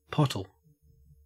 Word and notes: pottle: Australian English

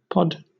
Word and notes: pod: British English

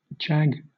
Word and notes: jag: British English

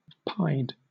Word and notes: pied: British English

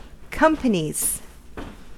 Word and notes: companies: American English

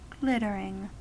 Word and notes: glittering: American English

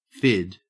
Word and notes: fid: Australian English

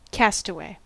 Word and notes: castaway: American English

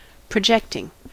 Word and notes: projecting: American English

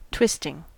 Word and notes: twisting: American English

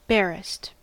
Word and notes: barest: American English

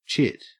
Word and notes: chit: Australian English

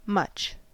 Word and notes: much: American English

